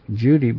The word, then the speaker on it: Cudi: Mount Judy is pronounced Judy, but spelled C-U-D-I.